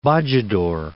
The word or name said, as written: Bojador, Cape